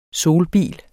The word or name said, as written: solbil